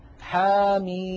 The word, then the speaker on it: حم: الميم من حاميم، نلاحظ بأن حا مددناها بمقدار حركتين فقط لأنه مد طبيعي، بينما ميم مددناها ست حركات لأننا نطقنا ميما مكسورة بعدها ياء ساكنة بعدها ميم ساكنة.